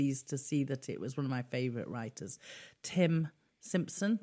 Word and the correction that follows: Stimpson: Oh, I always get his name wrong. – Stimpson. – That's it. Tim Stimpson. There always seems like too many T's in his name.